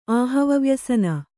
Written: ಆಹವವ್ಯಸನ

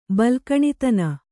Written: ಬಲ್ಕಣಿತನ

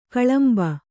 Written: ಕಲಂಬು

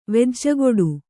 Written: ವೆಜ್ಜಗೊಡು

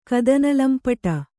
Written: ಕದನಲಂಪಟ

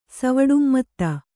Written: ಸವುಡುಮ್ಮತ್ತ

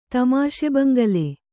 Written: ತಮಾಷೆಬಂಗಲೆ

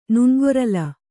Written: ನುಣ್ಗೊರಲ್

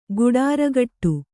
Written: ಗುಡಾರಗಟ್ಟು

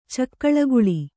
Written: ಚಕ್ಕಳಿಗುಳಿ

